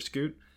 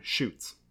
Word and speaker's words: scute: And we'd bring it up to that person and be like, hey, you know it's pronounced scoots? Like scoot over. Here's your scoot. But still, every single time, shoots. Not only that, this person had seen it spelled S-C-U-T-E, scoot.